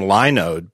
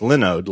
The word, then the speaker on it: Linode: I used to pronounce them Linode, but it's Linode, like Linux, because it's Linux hosting.